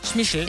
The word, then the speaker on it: Schmeichel: Det var fordi, der var en historie fremme om, at vi altid har udtalt deres familienavn forkert. Vi skal udtale Schmissel. – Schmissel, ja.